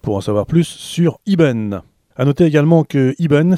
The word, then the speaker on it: Eben: Alors ça s'écrit E-B-E-N, ça se prononce Iben car c'est du breton.